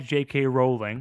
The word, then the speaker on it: Rowling: Let us like J.K. Rowling. Am I saying that right? – That's correct, yes. Okay. Rolling like bowling.